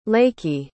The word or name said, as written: Lakey